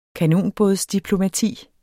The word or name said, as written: kanonbådsdiplomati